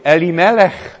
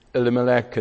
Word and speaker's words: Elimelech: I was waiting to see what our chairman was going to, how he was going to pronounce it, because for years I always pronounce it in the Welsh way, Elimelech, I pronounce it, and now I've got to, in America, Elimelech. So it's going to be Elimelech, all right.